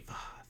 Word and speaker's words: Voth: Yeah, like that is, that sound is how you say Voth.